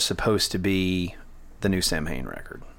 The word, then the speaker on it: Samhain: And by the way, it's pronounced Samhain, kids. That's right out of his mouth.